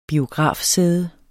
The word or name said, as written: biografsæde